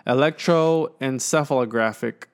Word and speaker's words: electroencephalographic: He performed a physical examination, a neurological examination, as well as an electrocephalographic exam. So that's brain scans, EEG. Electroencephalographic exam.